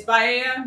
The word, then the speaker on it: Biaya: And it's Baia, not Baia or any of the other iterations that we get. – But it's spelled B-I-A-Y-A, right? – Yes.